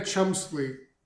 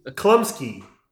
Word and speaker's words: Chlumsky: Anna Chlumsky. – You're not saying that right. – No, Chlumsky. – Chlumsky. Chlumsky? My Girl is exactly correct.